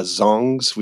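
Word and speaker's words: dzong: But, you know, apart from the birds, the other thing about Bhutan is that the architecture, the temples and the dzongs, which are fortress monasteries that you can go visit and see. – How do you spell that word? Is it D-Z? – Yeah, D-Z-O-N-G. D-Z-H-O-N-G? Okay. Yeah.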